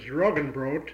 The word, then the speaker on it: Roggenbrot: Well, the German pronunciation was Roggenbrot, uh-huh.